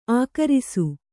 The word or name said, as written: ಆಕರಿಸು